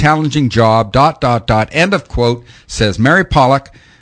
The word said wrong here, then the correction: Polak: Polak, actually, she pronounces her name, Children and Families Development Minister.